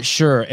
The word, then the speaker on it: Sherr: Dr. Scott Sherr.